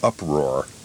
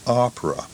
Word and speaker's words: opera: Part 2 of the program was called The Uproar. Now, uproar, let us hasten to explain, is Major J. Andrew White's way of pronouncing opera.